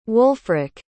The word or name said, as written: Wolfrick